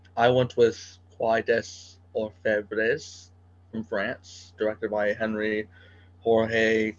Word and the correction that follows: Georges: Is Henri Georges Clouzot?